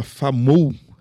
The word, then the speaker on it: Famou: Que se chama Famou, é certo, né, a pronúncia? – Isso.